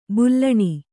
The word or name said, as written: ಬೆಲೂನು